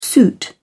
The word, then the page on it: suit: British English